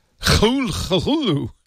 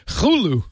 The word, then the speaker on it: Cthulhu: Actually, I'm looking now, by the way, and apparently Lovecraft himself pronounced it Cthulhu. Cthulhu. Cthulhu.